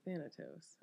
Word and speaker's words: Thanatos: Let's see, Thanatos. I don't even know if I'm saying that right.